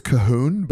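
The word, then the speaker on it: Colquhoun: It's Cahoon, but it's spelt C-O-L-Q-U. And you don't say the L, the Q, or the U, obviously.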